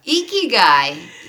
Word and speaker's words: Ikigai: Ikigai. Ikigai. – That's how I've heard it pronounced, but apologies to any Japanese listeners because our Japanese is probably abysmal.